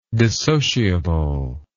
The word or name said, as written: dissociably